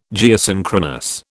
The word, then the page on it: Geosynchronous: British English